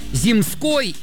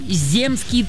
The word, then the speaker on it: земский: Земской слово или земский? Вот в каком из слов нужно правильно поставить ударение? Выбирайте. – Выбирай. – Земский? – Да. И правильно думаете.